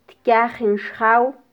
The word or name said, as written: tkyajin xjaw